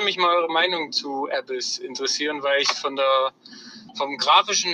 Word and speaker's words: Abyss: Nee, also, er hat es einfach nur trashig ausgesprochen, das heißt Abyss.